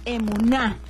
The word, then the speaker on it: emuná: Y el día de hoy la palabra es emuná. Acento en la A. Emuná no lleva H.